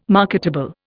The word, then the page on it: marketable: Indian